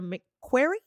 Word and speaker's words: McQuarrie: Directed by Christopher McQuarrie. Yes. I believe that's how you say his name. That's it.